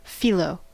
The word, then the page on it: phyllo: American English